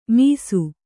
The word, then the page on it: ಮೀಸು: mīsu